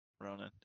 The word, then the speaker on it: Ronan: Rono? How did you get Rono Rona? Out of that? Ronin. – Ronin. There you go. – R-O-N-A-N.